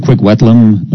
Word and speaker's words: Kwikwetlem: And also, Norm, this Ron Giesbrecht of the Kwikwetlem, is that how you pronounce it, Kwikwetlem? – That's correct.